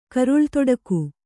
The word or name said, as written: ಕರುಳ್ತೊಡಕು